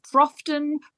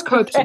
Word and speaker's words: Copeton: I do get a lot of Crofton, Compton. It's Copeton.